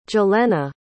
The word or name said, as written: jalena